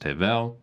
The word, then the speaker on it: Hevel: In tandem with this is the fact that Abel, in Hebrew, properly pronounced Hevel, is exactly the same as the word vanity, passing breath.